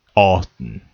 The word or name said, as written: Austin